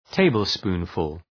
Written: tablespoonful

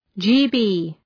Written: GB

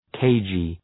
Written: cagey

